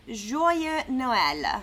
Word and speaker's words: Joyeux Noël: So it's Joyeux Noël.